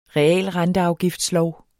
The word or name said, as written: realrenteafgiftslov